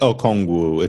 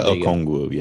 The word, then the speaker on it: Okongwu: There's obviously like in the case of Onyonwu, is that how you say it? – Okongwu. It's Onyeka Okongwu.